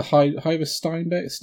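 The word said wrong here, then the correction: Harder & Steenbeck: Harder and Steinbeck.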